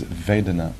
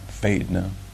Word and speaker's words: Vedana: So what we call the second foundation, the word in Pali is Vedana. And if you pronounce it with a Southern American accent, it sounds Vedna.